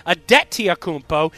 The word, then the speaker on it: Antetokounmpo: After I watched the movie, I've been pronouncing his name incorrectly. It's Giannis Antetokounmpo, and